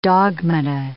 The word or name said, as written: dogmata